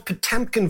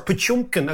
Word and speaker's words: Potemkin: And it's called Potemkin villages, or Potyomkin, as the Russians would pronounce it, villages.